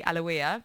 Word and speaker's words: Aloia: And next Monday I'm going to be heading to the third place just down the road from here to see Aloia. And the last time I saw her was at the Scala. So I am so totally buzzed to be getting a much more intimate experience. Her name's pronounced A-L-E-W-Y-A, if anyone is interested.